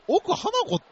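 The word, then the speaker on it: 奥華子: 奥花子って読むんや、これ。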